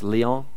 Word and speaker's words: Lyon: And if I get my pronunciations, for those of you who know French, just it's Lyon. Is it right? Am I right? Okay, good.